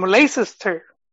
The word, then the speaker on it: Leicester: Uh, they have another striker on loan from Leicester.